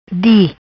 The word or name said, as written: Di